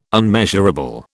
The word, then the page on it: unmeasurable: British English